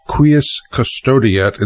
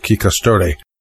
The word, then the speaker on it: Quis custodiet: Now, by the way, one of our Latin scholars on the list corrected my pronunciation of the tagline there. Apparently it's Quis Custodiet instead of Qui Custode.